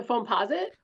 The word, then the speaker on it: Foamposite: A great example of what you're talking about is the Nike Air Foam Post One. Was that how you pronounce it? – The Foamposite? – Foamposite One, yeah.